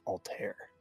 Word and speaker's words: Altair: Yeah, I figured there'd be a lot of Assassin's Creed fans listening too, and they'll know that word as Altair. But my understanding is that the star is pronounced Altair.